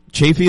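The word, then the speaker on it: Chaffee: And also his mother, Nancy Chafee, is that how I pronounce it, Scott? – That's correct, yes.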